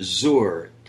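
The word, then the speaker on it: Zor (זר): And very interestingly, Junie, the word for strange in Hebrew is a word that's pronounced Zor. Perhaps if we were to spell it phonetically, it would be Z O O R.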